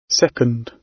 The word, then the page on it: second: British English